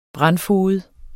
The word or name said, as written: brandfoged